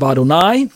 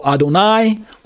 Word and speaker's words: Adonai: On the right side, you have Adonai with the little vowel markings that indicate the Adon plus I-sounding vowels.